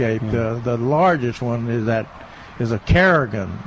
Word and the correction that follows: carrion: Uh, am I saying it right? The the flower smells like uh, dead meat, carrion, yeah.